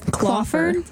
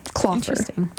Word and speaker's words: Kloepfer: Clawfer. K-L-O-E-P-F-E-R. Clawfer. I think that's how you say it. – No, that's probably right. – Clawfer.